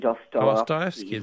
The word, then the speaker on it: Dostoevsky: It's Dostoevsky, Dostoevsky.